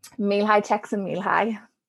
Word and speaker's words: Mihály Csikszentmihalyi: Could you say it one more time? – Mihály Csikszentmihalyi. Yeah.